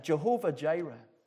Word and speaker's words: Jehovah Jireh: And I actually watched some Hebrew videos on pronunciation of that word. So I've got it as well as it is on the internet. Jehovah Jireh.